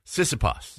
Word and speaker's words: Tsitsipas: It's a Tsitsipas. Stefanos Tsitsipas.